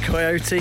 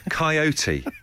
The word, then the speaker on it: coyote: Coyote, not coyote. You say it like coy carp and it winds me up.